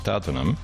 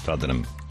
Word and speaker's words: Tottenham: En la pronunciación British sí. Es más Tottenham. Con esa O hecha A, que es más americano que British en realidad.